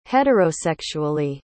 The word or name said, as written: heterosexually